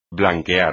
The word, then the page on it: blanquear: espagnol